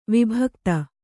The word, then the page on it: ವಿಭಕ್ತಿ: vibhakti